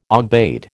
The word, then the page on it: aubade: American English